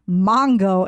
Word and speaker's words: mango: You even posted a video on your vacation saying Mongo instead of mango, and that is so right, because he does say that, Carly. – Well, I do, Carly Hunt, you're exactly right. I do say mango because that is the Spanish pronunciation.